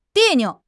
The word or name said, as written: 别扭